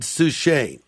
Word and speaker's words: Suchet: But there are interactive moments where you can press a little button and you hear the voice of the tremendous actor David Suchet.